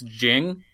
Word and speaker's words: Ging: So as it's pronounced, it's Jing, which, you know, there's not really a good pun there. But as it's spelled, it's G-I-N-G, so like Ging.